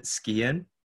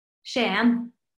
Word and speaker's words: Skien: I've actually got um you're from a place called I think you pronounce it Skien. – Shan. – Okay. I got it completely wrong.